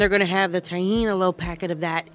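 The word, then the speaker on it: tahini: Pop-Tart and tahine. Am I saying it right? – Tahine. Yeah, okay.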